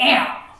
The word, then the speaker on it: Amn: So I'm wondering if your path led more to that mountainous region called the Am. It's spelled A-M-N. The N is silent, I have been told. Otherwise it would sound really weird, like M.